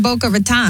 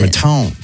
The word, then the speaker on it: Boca Raton: What's wrong with Boca Raton? – Well, that's not how you pronounce it. – How do you pronounce it? Raton. – Raton, Raton, tomato, tomato. Yes.